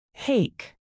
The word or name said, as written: hake